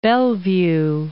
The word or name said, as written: Bellevue